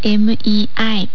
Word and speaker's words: 没: 没有、没の発音は MEI、没、二世と発音します。